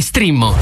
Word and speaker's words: Streammo: Vai su strimmo.it. Si scrive Streammo o scarica l'app.